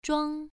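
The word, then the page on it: zhuang: tone 1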